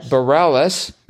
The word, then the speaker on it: Bareilles: Which meant that 45 across, the aforementioned love singer Bareilles, is that really how it's pronounced? – Mm-hmm. – Okay. I keep thinking it should be pronounced Bare because it looks vaguely French.